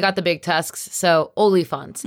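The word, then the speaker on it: oliphaunt: The servants also have elephants and Sam pronounced elephants very weirdly. – Olyphants. They're called olyphants. – Okay. – They're not elephants.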